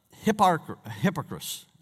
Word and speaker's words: Hipparchus: Hipparchus, I think is the way you pronounce it.